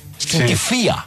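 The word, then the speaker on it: Skinty Fia: ¿Skintifaya? – Skintifia, sí. Vi un video de cómo lo pronuncian. Fía. Porque pues eso no es una palabra, sí. Entonces es como una expresión.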